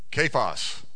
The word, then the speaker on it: Kephas: You shall be called, how do you say it? Kephas.